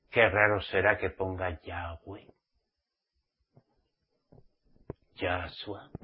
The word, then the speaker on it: Yahweh: Hoy en día los llamados mesiánicos de pacotilla, que son cristianos disfrazados de mesiánicos, en vez de pronunciar el nombre de Yahweh, YHWH, Yahweh nos dice que pronunciemos su nombre, Yahweh.